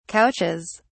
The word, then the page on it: couches: British English